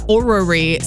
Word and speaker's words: orrery: So it's O-R-R-E-R-Y. Orrery. – It's Orrery.